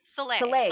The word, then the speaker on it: Soleil: I don't think you use the last L, though. – Soleil. – Like, thank you. Soleil. Yes, yeah.